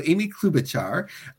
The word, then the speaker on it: Amy Klobuchar: So I think I only know how to pronounce her name well from like Saturday Night Live or something. So Amy Klobuchar. – There you go.